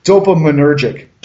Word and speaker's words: dopaminergic: Dopaminergic.